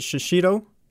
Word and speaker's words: shishito: Is it pronounced with a double shishish? Is it shishito? – That's what I thought. Shishito. That's how, there's that's no extra word. H in it though. Why are you putting? – But the language is weird, dude. – I said shishito, not a shido.